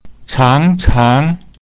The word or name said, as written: chang2chang2